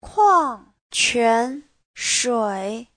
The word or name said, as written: kuang4quan2shui3